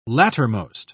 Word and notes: lattermost: British English

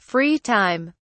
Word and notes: free time: British English

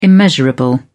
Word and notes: immeasurable: British English

